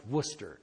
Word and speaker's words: Worcester: and they tell me it's spelled Worcester, but it's pronounced Worcester. And if you're from that part of the country, it's Worcester. Worcester Polytechnic Institute.